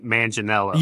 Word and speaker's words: Manganiello: He uh Joe Man- Manganiello.